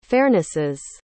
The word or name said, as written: fairnesses